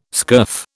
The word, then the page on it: scurf: British English